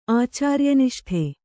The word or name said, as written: ಆಚಾರ್ಯನಿಷ್ಠೆ